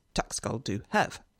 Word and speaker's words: tak skal du have: H-A-V-E, same word, tak skal du have. So if you were to read that out, you'd go tak skal du have. How do the Danes pronounce it? Tak skal du have. Tak skal du have. Mm-hmm. Where are the consonants? They seem to have dropped them somewhere on their way.